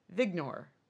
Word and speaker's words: Viognier: V-I-O-G-N-I-E-R. Vignor. – Vignor? Probably Vignor. – Well, I mean, it's in Virginia, so it's how they pronounce it.